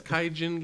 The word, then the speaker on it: Kaigan: Is it Kaigen Games? Kaijin Games? – Oh, don't say it like that. – K-A-I-G-A-N Games is the developer. – I'm gonna say Kaigen. – Kaigen, I think.